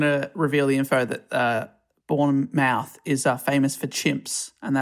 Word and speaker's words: Bournemouth: I think English people will be annoyed by that pronunciation. It's Bournemouth. we'll get tweets if we don't correct it. – Yeah, thank you for Bournemouth.